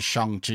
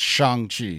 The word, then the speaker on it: Shang-Chi: Go follow Simu Liu's Twitter and Instagram, and he says what the correct pronunciation is, Shang-Chi. And when Kevin Feige first announced it at San Diego Comic-Con, he even came out and said it's Shang-Chi. So S-H-O-N-G.